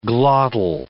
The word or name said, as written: glottal